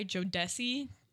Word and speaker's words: Jodeci: And Fayze caught the attention of, I'm gonna say this wrong, it's J O D E C I, Jodeci, I think. – Yes. – Um, that sounds Jodeci. exactly correct.